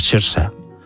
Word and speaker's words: Saoirse: que como es gaélico es Saoirse, es como se pronuncia.